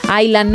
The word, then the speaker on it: Island: En Island no pronuncian la S.